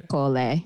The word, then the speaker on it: Ercole: Ercole.